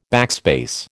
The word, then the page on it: backspace: American English